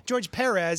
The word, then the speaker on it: George Perez: George Perez. That's how he pronounced it.